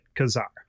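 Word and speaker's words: Ka-Zar: Did you know that Kazar was pronounced Kazar? I grew up pronouncing it Kazar. – Yeah, I knew it was Kazar.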